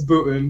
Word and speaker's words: Boonton: Am I saying that right, Boonton? – It's Booton, but I give you credit.